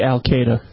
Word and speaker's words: Al-Qaeda: I say Al-Qaeda. – Al-Qaeda. It's spelled A-L-Q-A-E-D-A. So Al-Qaeda. Al-Qaeda, the E influences the A sometimes, depending. I mean, English is a strange language.